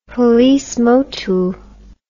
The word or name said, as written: policemotu